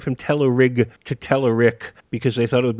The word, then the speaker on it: Telerik: Okay, because I've always heard Telerik is the right way, and somebody go and tell Hanselman that it should be pronounced Telerik, I guess.